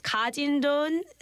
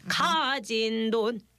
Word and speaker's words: 가진 돈: And in the phrase 가진 돈, stress the first character 가. – 가. – 가진 돈.